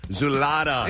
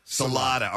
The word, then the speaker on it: Czulada: How did I get your name correct? – You did this time, sure. Yeah, you just experience whatever freshman feels when they walk in. It's high anxiety. – You pronounce your last name. So how do you pronounce it? – Salada. – Salada. All right. C-Z-U-L-A-D-A.